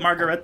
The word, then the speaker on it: Margaret: Can we call it Margaret then for the rest of the podcast? – Okay, let's work on it.